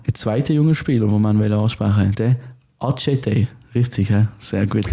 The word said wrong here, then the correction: Adjetei: Habe ich mich gestern mehrfach belehren lassen. Wir haben das, glaube ich, mehrfach auch schon falsch ausgesprochen hier im Podcast. – Adjetei, sagen wir, glaube ich. Adjetei, genau. Adjetei. Wir entschuldigen uns. Es ist also wirklich Adjetei.